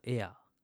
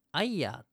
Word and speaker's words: air: AIR、エアー。空気ですよね。あれが読めなくて、アイヤーって読んだんですよ。